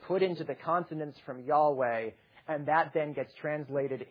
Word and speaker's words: Yahweh: And then again later on, Exodus 6:3 and other sections again make reference to this name, which we would pronounce it Yahweh, typically, though actually we don't really know how the Hebrews pronounce it.